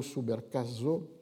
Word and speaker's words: Subercaseaux: La seule autre exception étant, mais une entreprise plus solitaire, en 1993, donc très tôt, un collègue chilien, Bernardo Subercaso, ça s'écrit S-U-B-E-R-C-A-S-E-A-U-X, avait publié une Historia del libro en Chile.